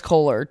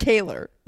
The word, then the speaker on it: Kohler: And their last name is Kohler too. I think we knew that. – Oh yeah. – Yeah. – Not Kaler. – Oh yeah. – That's how someone else pronounces it.